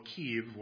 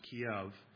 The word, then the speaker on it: Kyiv: Okay, they're in the capital of Kyiv, or we might pronounce it Kiev.